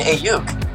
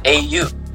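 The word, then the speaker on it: Aiyuk: Brandon Ayuk. – Dre C. Fab is on the clock. – That's how you pronounce it. Ayuk. – Ayuk.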